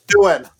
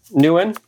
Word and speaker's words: Nguyen: Nguyen. – Nguyen.